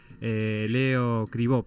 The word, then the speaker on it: Leo Cribó: Arranco con el viernes y ya estamos en comunicación con Leo Escribo, que va a estar presentando el espectáculo Huellas, Cuentas Monologueadas. Leo Cribó, perdón, lo pronuncié mal.